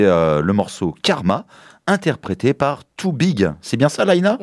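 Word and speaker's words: 2BYG: Parce que ça s'écrit avec le chiffre 2 et B-Y-G. Ouais. Alors, on se dit Too Big.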